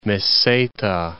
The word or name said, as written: Meseta